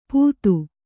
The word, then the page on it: ಪುದು: Kannada